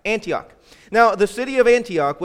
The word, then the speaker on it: Antioch: Now, if you don't know how to pronounce Antioch, just imagine that you had an aunt and her name was Ock.